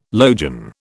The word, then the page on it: logion: British English